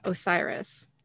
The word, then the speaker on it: Osiris: Bobby says, they identify the god Osiris. Did I say that right? Yes.